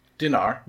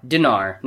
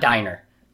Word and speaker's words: dinar: I'm going to say it wrong. Dinar? – Dinar. – Dinar, not diner.